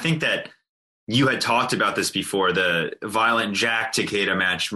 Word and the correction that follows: Violento: Violento, yes, Violento. – That was Sorry, a, I said it wrong.